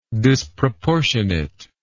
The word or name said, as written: disproportionateness